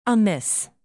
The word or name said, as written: amiss